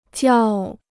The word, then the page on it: 较: jiào